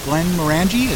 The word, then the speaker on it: Glenmorangie: There is a Old Pulteney 12 and a Glenmorangie. Is that how you say it? – Yeah.